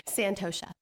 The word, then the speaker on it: Santosha: The word for the year is Santosha.